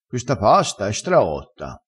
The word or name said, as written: straotto